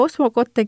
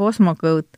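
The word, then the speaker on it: Osmocote: no vot seda, kuidas keegi tahab, kes ütleb osmokote, kes ütleb osmokõut. meil on ju see, et, et kas me nüüd loeme nii nagu vene keeles või nii nagu saksa keeles see hääldus või mõned ütlevad veel, et prantsuskeelne hääldus peab olema.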